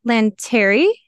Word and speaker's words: Lanteri: And today I have a very special guest, Danielle Lanteri.